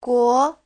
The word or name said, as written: guo2